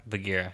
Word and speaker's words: Bagheera: Bagheera, yeah.